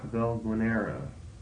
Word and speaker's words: Valguanera: Valguanera.